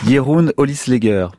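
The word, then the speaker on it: Jeroen Olieslagers: et on le doit à un auteur dont je vais essayer de prononcer le nom correctement, Jeroen Olieslager. – Parfait.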